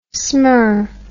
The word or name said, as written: smir